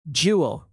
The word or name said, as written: jewel